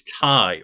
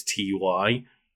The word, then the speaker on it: Ty: My older sister less so, but me and my younger, and like we always argued of whether it was Ty or whether it was T Y. – I always pronounce it Ty because it's the guy's name.